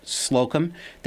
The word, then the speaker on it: Slocum: It's Slocum. I'll say it and spell it. Slocum, that's S as in Samuel, L-O-C-U-M as in Michael.